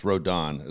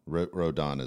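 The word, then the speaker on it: Rodón: By the way, it's Rodon, as my son always freaks out that I say it wrong. I think I said Rodon. Rodon is how you pronounce it, as I get yelled at all the time.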